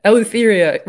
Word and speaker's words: Eleutheria: Eleutheria rhymes with diphtheria.